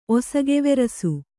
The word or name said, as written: ಒಸಗೆವೆರಸು